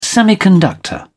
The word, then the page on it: semiconductor: British English